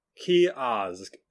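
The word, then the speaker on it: Ceasg: Kiosk is one of the pronunciations.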